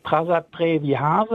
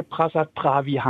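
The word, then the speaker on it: Prasat Preah Vihear: Das kommt darauf an, ob Sie die kambodschanische oder thailändische Aussprache wählen. Auf kambodschanisch würde man Prasat Prea Vea sagen, auf Thai Prasat Prea Vea Ham.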